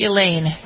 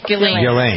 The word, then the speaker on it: Ghislaine: How is it pronounced? – Ghislaine. – Ghislaine. Okay, silent S.